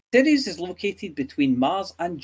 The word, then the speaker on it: Ceres: Space Facts this week looks at Ceres. I'm saying that right, aren't I? – Ceres, yeah, not Ceres or Karen or whatever.